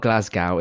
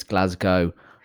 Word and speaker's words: Glasgow: Wasn't it in Glasgow? – In where? – Glasgow? – Okay. Yeah, no. – Was it? – No, it's not Glasgow. It's Glasgow.